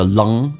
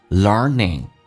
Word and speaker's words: learning: And keep learning, learning 这个单词有点难发，首先在美式发音当中是重度卷舌，整个舌身往回卷，learning，鼻音往后发，不要 long， no， learn，整个舌身往回卷，learning。